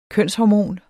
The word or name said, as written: kønshormon